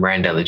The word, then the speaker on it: Randell: And for myself, I'm Randell J. That's spelled R-A-N-D-E-L-L. Last name is A-D-J-E-I.